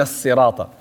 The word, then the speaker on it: صراط: Ihdina sirata. Sad. ook wanneer hij een kasra heeft, dient zwaar uitgesproken te worden. Anders wordt hij een sin. Ihdina si. Niet si. Ihdina sirata. Het is ihdina sirata al-mustaqim.